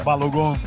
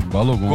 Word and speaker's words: Balogun: Leandro Boldaquian, Estados Unidos e Bélgica, Leandro, com Balogun. Ou Balogun, como é que se pronuncia? – Balogun. – Balogun? – Balogun.